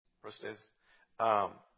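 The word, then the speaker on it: prostatis: How would you say that? Prostatus.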